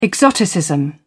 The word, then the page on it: exoticism: British English